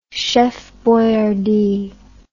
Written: Chef Boyardee

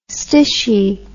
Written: stishie

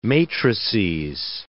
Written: matrices

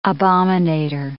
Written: abominator

